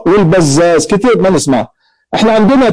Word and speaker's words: البزاز: مثلا البزار بالراء المهملة والبزاز بالزاي المعجمة، البزار والبزاز هذه نسبة، هو ما اتفقت صورته في الخط اهو بزار وبزاز الاثنين متفقين في الخط ولكن اختلفت في النطق.